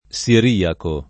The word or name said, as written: siriaco